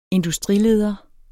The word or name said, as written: industrileder